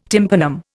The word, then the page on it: tympanum: Indian